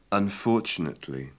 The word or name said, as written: unfortunately